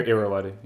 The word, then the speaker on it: Irrawaddy: I mean, that's all sort of enclosed within like the confines of how do you pronounce it? The Irrawaddy River, Irrawaddy, yeah.